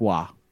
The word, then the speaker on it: Roy: There's a hockey player, Patrick, last name R-O-Y. How would you pronounce that? – Roy. – Wrong. No. It's Patrick Waugh.